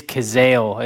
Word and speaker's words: Cazale: Am I saying that right? – It is Cazale. It's Cazale because it says on the Wikipedia how to pronounce it.